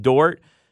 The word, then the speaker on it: Dort: And they've got my boy named Dort, I believe is how I pronounce it. – Lou Dort, yes.